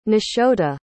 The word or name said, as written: nashota